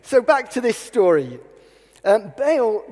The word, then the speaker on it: Baal: I was always taught to call him Baal. I've heard lots of people call him Baal, and other people call him Baal. So I looked it up. The Oxford English Dictionary says that you should pronounce it Baal, but apparently the American pronunciation tends to be Baal, and they claim that's closer to the original Hebrew.